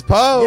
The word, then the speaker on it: Poe: It is Poe.